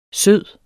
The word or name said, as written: sød